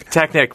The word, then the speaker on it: Technik: Am I saying Technik right, or should I say Technik? – Technik, pronounced the German way.